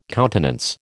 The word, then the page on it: countenance: American English